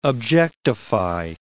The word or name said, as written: objectify